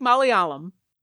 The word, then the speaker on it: Malayalam: If you learn to speak Malayalam.